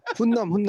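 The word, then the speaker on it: Hunnam: 발음 발음을 똑바로 하셔야 되는데. 훈남 훈남. – 어. – 찰리 훈남. 어, 훈남.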